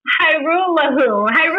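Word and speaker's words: Hajrullahu: So agreed to terms with two kickers, former XFL kicker Austin McGinnis and former CFL kicker Liram Hyrulia. – I was going to wait for you to pronounce it because I wasn't going to try it. – Hyrulahu.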